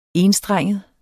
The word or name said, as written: ENSTRENGEDE